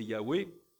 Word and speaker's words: Yahweh: Alors, dans la Bible, c'est un tétragramme, YHWH, qui vraisemblablement peut, sans trop risquer d'erreur, se prononcer Yahweh,